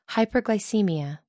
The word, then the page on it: hyperglycaemia: American English